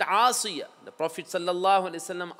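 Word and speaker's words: Asiya (آسية): Unfortunately, amongst us, especially Asian people, we don't differentiate between the pronunciation of Ayn and Hamza. It's Asiya.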